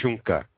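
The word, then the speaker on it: šuŋka: It's Shunka, T-S-U-N-K-A, but they pronounce it like Shunka, and because when the white people came, they didn't have any other word for it.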